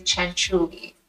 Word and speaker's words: Cianciulli: And the pronunciation is Cianciulli, Italian.